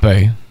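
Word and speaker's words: Mbappé: Argentina and France, Sunday morning, Lionel Messi going for his first World Cup, and Kylian Mbappe, am I saying that right? – Mbappe.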